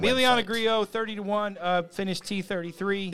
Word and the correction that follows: Grillo: It's Grillo again, by the way. – Grillo. That's what I said.